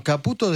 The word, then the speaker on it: Caputo: Bueno, Caputo descartó emisiones de deuda internacionales. ¿Lo dije bien, Alcázar? – Sí, perfecto, siempre bien.